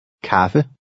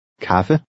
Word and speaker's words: Kaffe: Since the A is followed by two consonants, it makes a short A sound. Listen and repeat. – Kaffe. Kaffe.